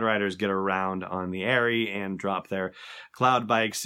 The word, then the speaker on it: aerie: Pronunciation stuff on Star Wars 7x7 again, as in an eagle's nest, an Airy, A-E-R-I-E, not as in the Abercrombie and Fitch brand or whatever it is.